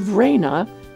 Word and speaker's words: Reyna: Oh, and while you're there, I posted a photo of Raina, that's spelled R-E-Y-N-A, Raina and her children.